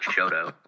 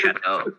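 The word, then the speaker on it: Chouteau: Yeah, I'm going to say it's not Shodo. It's Shodo, but yeah, that's fine.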